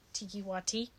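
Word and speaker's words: Taika Waititi: Tiki Wati. – Yeah. – Ooh! That's the first time I said his name right!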